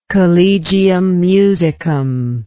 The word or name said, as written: collegium musicum